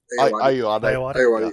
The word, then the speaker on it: Ayoade: Richard Ayoade.